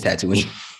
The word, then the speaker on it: Tatooine: And just so you know, Gerard, that planet is Tatooine.